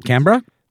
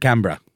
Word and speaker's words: Canberra: Canberra? – Is that how you pronounce it? – I think that's how the Aussies pronounce it. – Like Melbourne. – Exactly. Melbourne and Canberra.